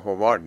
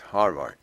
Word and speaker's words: هاروارد: بالا دانشگاه هاروارد میگن، من بکنم هاروارد گفته باشم، هوارد نگفته، شاید اشتباهی در لفظ و بیانم شده، وگرنه دانشگاه هاروارد که برای همه شناخته شده است، دانشگاه هوارد نیست، هاروارد، چون حالا آرش معمولا خونده نمیشه، یعنی کمتر تلفظ میشه، شاید به نظر این چنین آمده.